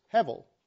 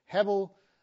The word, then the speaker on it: hevel: And the word there for vanity is hevel. Sometimes spelled with a B, sometimes with a V, but hevel is a word that basically means a breath or a vapor.